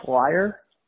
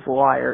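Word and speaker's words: Lyre: I think their name is pronounced Liar.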